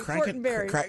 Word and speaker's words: Fortenberry: I missed you, but you saw my good friend, Congressman Jeff Fortenberry from the great state of Nebraska. – Fortenbarker.